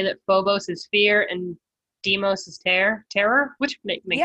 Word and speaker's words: Deimos: Ares had two children, Phobos and Deimos? Demos? – How do you spell it? – D-E-I-M-O-S. Deimos? – Deimos.